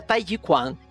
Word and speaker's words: Tai Chi Chuan: É, por isso que geralmente nas praças tem um monte de velhinho, né, lutando, fazendo o Tai Chi Chuan. – E a pronúncia certa é Tai Chi Quan.